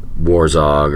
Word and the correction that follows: Herzog: Werner Herzog and okay.